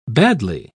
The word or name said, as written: badly